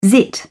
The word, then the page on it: zit: British English